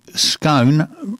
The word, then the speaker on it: scone: Scone rhyming with gone is wrong. So scone and gone. It's not right, apparently.